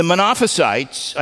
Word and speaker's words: Monophysites: The Eutychians and the Monophysites, I said it right that time, the Monothelites and Monophysites, they all went to the opposite extreme.